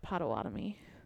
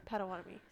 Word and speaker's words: Potawatomi: I got distracted by the way you said Potawatomi. – Potawatomi. Sorry.